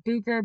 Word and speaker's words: Bucher: Sierra Bucher, B-U-C-H-E-R, Butcher without the T.